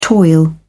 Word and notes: toil: British English

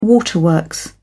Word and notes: waterworks: British English

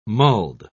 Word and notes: mulled: British English

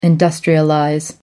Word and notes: industrialize: American English

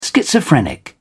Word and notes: schizophrenic: British English

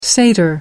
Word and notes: satyr: American English

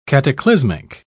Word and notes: cataclysmic: British English

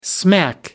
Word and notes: smack: American English

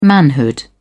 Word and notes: manhood: British English

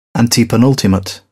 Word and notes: antepenultimate: British English

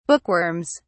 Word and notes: bookworms: British English

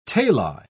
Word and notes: tali: British English

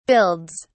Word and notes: builds: British English

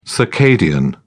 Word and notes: circadian: British English

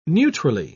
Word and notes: neutrally: British English

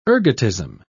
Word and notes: ergotism: British English

